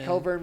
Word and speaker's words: Cockburn: There's so many. It's Kobe so Coburn and yeah, Coburn went spelled like the other way. – Cockburn.